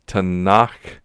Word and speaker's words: Tanakh: Three letters, Tet, Nun, and Kaf, Tanakh, if you were to pronounce them.